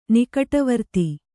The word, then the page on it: ನಿಕಟವರ್ತಿ: nikaṭavarti